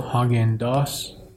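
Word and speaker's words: Häagen-Dazs: هاگنداس اگه اشتباه نکنم تلفظش رو هاگنداس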